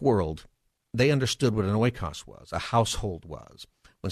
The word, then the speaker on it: oikos: You might pronounce it in modern Greek. It would be ekos, and it's the root word for ecosphere or economy. Academically, you typically say oikos, so that's what I'm going to say.